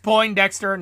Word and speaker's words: Poindexter: It's pronounced Poindexter, not point. It's Poindexter.